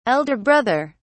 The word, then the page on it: elder brother: British English